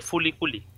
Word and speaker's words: FLCL: Ah, porque pronuncian FLCL Furikuri.